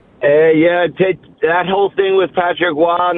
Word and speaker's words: Patrick Roy: It's spelled ROY, but you say Patrick Roy.